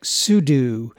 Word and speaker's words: sudo: Most people pronounce it like the word pseudo, P S E U D O. Back in the Unix days, us oldies pronounced it like sudo. The name actually stands for switch users and do, which is probably why we pronounce it that way.